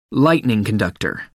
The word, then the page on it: lightning conductor: American English